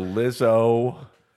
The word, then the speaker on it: Lizzo: So do you know, so Lizzo. – Do you spell that like Rizzo, but with an L? – Yeah.